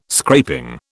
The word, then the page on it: scraping: British English